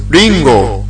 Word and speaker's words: Ringo: Oh, my name is Ringo. – Ringo? Sorry, how do you spell that? – R-I-N-G-O.